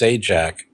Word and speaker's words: Sajak: I think it's pronounced Sajak, and the first name is pronounced Pat.